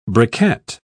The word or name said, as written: briquette